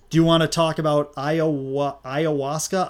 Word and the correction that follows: ayahuasca: How do we pronounce this? – This ayahuasca isn't going to drink itself.